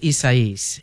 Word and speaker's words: Isaias: We, you know, I've heard it pronounced a bunch of different ways, but the most recent was Isaias.